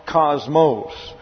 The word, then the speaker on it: kosmos: K-O-S-M-O-S. Cosmos. And when it comes over into the English, comes over into the English with a C here, a hard C, but it's pronounced cosmos. Well, these are omicrons and therefore get a short A sound, so it's really cosmos.